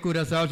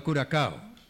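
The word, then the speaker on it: Curaçao: No se dice Curazao, sino Curacao.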